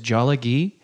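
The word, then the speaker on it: Tsalagi: Cherokee language is the word for it is Jollygee.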